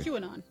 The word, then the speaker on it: QAnon: One of us is also wrong about the pronunciation of QAnon, because that's what it is, according to the texter at 52353. – QAnon.